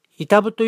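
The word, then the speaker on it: 飯給: あとご飯の飯に給食の給と書いて板部と読む駅があるんですけど、ここに駅前に無駄に広い壁に囲まれたスペースがあります。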